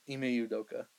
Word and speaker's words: Ime Udoka: Rockets, Imeduka. Say it right, right? – Ime Udoka. – Ime Udoka. I'm sorry about that.